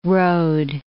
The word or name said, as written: road